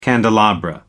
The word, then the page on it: candelabra: American English